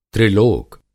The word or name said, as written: त्रिलोक